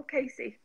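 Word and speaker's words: Cayce: Is it Safdie or Casey? – Edgar Casey, yeah.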